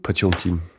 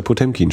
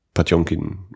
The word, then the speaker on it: Potemkin: Ich habe früher auch lange Zeit gedacht, Panzerkreuzer Potemkin steht doch da, also spricht man das so, bis ich dann irgendwann mal darüber äh, aufgeklärt wurde, dass man das Potjomkin ausspricht.